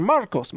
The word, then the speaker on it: San Marcos: Like, Marcos, Marcos, right? But in Texas, here's how they say it. San Marcos. it's San Marcos. I'm like, no, Marcus is M-A-R-C-U-S. Marcos is O-S. But they're like, nope, it's Marcus.